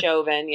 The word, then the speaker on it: Chauvin: It's Derek Chauvin? – Chauvin, yeah.